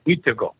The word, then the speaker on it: Wendigo: Wendigo is Wendigo, the way we pronounce it, Wendigo.